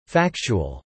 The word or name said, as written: factual